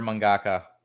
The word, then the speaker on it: mangaka: Yeah, he's a Japanese horror mangaka. – Yeah. – And mangeka. I always say that wrong. Mangeka. – Mangaka.